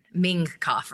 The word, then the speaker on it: Mingkoff: They spelled it M-I-N-G-K-O-F-F. So I guess it was spelled wrong. But that sounds like the Chinese version, Ming Koff, right? – It's like Ming Koff.